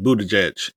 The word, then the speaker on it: Buttigieg: Buttigieg. Yep. – All right. Good. Transportation Secretary Pete Buttigieg.